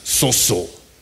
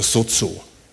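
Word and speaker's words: sozo (σῴζω): Ich glaube, das spricht man mit scharfem S, sozo oder sozo.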